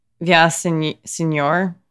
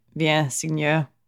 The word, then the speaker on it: Viens Seigneur: My first song is called um, "Via Senor," but if you pronounce it properly in French, like Viens Seigneur, whatever, by Keur Moussa, which is so this is a, a song they're singing in French.